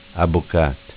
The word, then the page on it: Abocat: dialetto